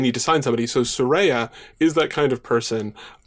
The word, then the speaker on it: Saraya: I also do a lot of the AEW post shows, and we talked a bunch about like Jade, Saraya. I want to pronounce it correctly. – Yes, please do. She will come from. – Yeah, Saraya.